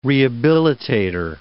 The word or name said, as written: rehabilitator